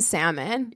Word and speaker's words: salmon: It's like, she just couldn't get over him pronouncing the L in salmon.